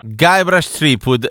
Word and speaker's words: Guybrush Threepwood: Guybrush Threepwood, spero che la pronuncia sia esatta, assolutamente sì. – Perfetta,